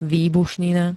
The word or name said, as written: výbušnina